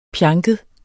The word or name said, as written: pjanket